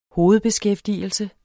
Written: hovedbeskæftigelse

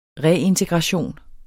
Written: reintegration

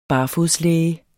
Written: barfodslæge

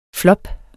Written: flop

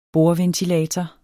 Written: bordventilator

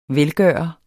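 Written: velgøreren

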